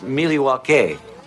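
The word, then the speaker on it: Milwaukee: Actually, it's pronounced Miliwake, which is Algonquin for the good land.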